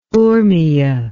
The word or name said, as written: Urmia